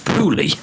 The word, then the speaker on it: poorly: Bearing in mind that people from Teesside pronounce the word poorly, as in P-O-O-R-L-Y, I can't say it properly because I'm from Teesside, pronounce the word poorly, P-O-O-R-L-Y, as Pooley, then this chant just sounds like we're making an observation about the low life expectancy and overall weak health of our town.